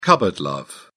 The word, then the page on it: cupboard love: British English